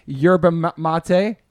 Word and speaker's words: yerba mate: Yeah, I'm drinking my yerba mate.